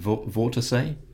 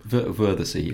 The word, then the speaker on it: Wörthersee: How would the Germans pronounce it? Vortesee? Wörthersee, as we know it, I think.